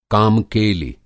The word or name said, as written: काम-केलि